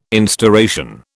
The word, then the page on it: instauration: British English